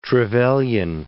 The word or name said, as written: Trevelyan